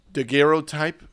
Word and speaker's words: daguerreotype: There's a fun little how do you say it? Daguerreotype? Daguerreotype? – They're called a daguerreotype.